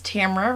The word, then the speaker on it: Tamara: Well, so my name is Tamara, rhymes with Camera, Winter, also known as Tammy for people who like suburban mom names.